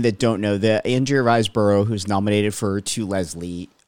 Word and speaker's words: Riseborough: It's actually Andrea, I think it's pronounced Riceboro.